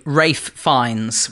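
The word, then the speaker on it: Ralph Fiennes: I really want to get to the fame levels of Rafe Fines, which is, you know, spelt Ralph, but pronounced Rafe.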